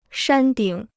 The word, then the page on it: 山顶: shān dǐng